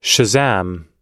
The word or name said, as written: shazam